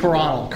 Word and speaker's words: Coronel: How do you pronounce Carnell? – Coronel.